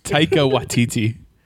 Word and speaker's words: Taika Waititi: Takia Watiti? Taika Waititi.